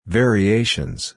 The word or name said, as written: variations